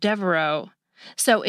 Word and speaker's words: Devereaux's: You just add an S to the end because, unlike the name Fox, you don't pronounce the X at the end of Devereaux. So it just gets a lone S to make it plural.